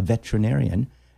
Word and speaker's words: veterinarian: Yesterday we had a lady come up, a veterinarian, and it is vetra or is it vet? – Veterinarian. – Veterinarian. Ah, I mispronounced it, didn't I? – Yeah.